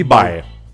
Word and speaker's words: Vai: Y el apellido se pronuncia Bay, porque es italiano. – Ok, Steve Bay.